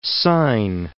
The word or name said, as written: syne